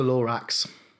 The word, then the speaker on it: Lorax: They also are no stranger to Dr. Seuss because they did the Lomax film a few years ago. – Mm-hmm. – Is it the Lomax? Am I saying that right? – It's the Lorax. – Lorax. Ah. Lorax film.